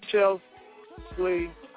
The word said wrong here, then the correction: Chesley: No, you said it wrong. – What? – It's Chesley.